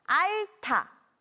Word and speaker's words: 앓다: 알이 받침 리을과 히읗으로 이루어져 있는데 알다가 아니라 알타, 알타라고 발음해요.